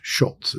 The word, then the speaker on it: Szulc: Tad Schultz, S-Z-U-L-C, pronounced Schultz. It's a Polish name.